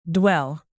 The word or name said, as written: dwell